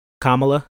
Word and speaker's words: Kamala: I saw like like because you know like when everybody was like highlighting Kamala, I said it right.